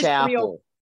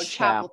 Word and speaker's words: Chappell: Is it Chappell? – It's Chappell. – There you go, Chappell.